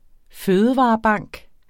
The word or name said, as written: fødevarebank